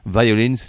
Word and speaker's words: Violins: Violins, les violons au pluriel, ça se prononce de la même façon à peu près en anglais que violence, c'est-à-dire la violence.